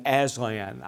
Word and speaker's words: Aslan: There was another great lion, Aslan. I was afraid I had mispronounced that. I was right on that.